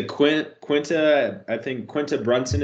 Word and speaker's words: Quinta Brunson: This is a Quinta Brunson is how you say her name.